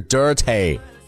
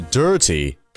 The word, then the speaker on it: dirty: 也就是字母 e 的发音了，所以说脏的不是 dirty，而是 dirty。